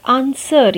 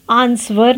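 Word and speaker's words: answer: आंसर यहाँ पर W silent है A N S W E R आंसवर नहीं कहते हम आंसर so W is silent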